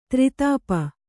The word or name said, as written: ದಾರಿದಪ್ಪು